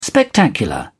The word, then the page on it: spectacular: British English